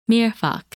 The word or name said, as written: Mirfak